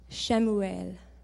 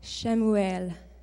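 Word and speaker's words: Shemuel (שמואל): So if the internet has not misled me, you pronounce this person's name as Shemuel. Shemuel.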